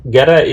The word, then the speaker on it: Gere: Gere or Gere? What's the pronunciation? – Gere. Gere. So, so Gere is uh, I'm I live in Romania, but as as a nationality, I'm I'm Hungarian. You know and uh, Gere Arpad is uh, it, so this is how it's pronounced in Hungarian Gere Arpad.